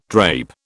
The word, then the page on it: drape: British English